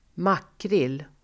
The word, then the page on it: makrill: [²m'ak:ril]